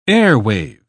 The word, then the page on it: airwave: British English